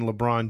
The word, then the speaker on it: LeBron: But we're going to talk about Akron, Ohio, and that is the hometown of one LeBron James.